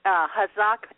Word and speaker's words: Hazak: And it is pronounced Hazock. – I'm sorry. – The word Hazock, that's okay. Hazak actually is Hebrew for encouragement.